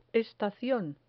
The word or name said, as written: estacion